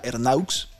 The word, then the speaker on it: Ernaux: Ernaux, de 82 años, que se pronuncia Ernaux,